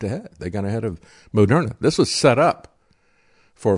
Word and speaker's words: Moderna: As a matter of fact, they pronounced their company's name at the time as Mode RNA. And then they changed it. They put the emphasis on a different syllable, and they called it Moderna.